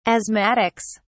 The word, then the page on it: asthmatics: British English